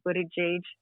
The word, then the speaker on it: Buttigieg: Um, you had Buttigieg, Buttigieg, that's how you say his name.